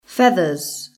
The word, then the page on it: feathers: British English